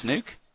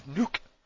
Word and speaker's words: Nuuk: Does it say it's pronounced Nuke? – Yeah, it says Nook.